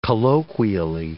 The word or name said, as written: colloquially